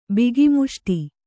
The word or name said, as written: ಬಿಗಿಮುಷ್ಟಿ